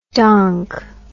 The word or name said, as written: donk